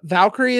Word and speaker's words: Valkyrie: And you get to see the Valkyrie.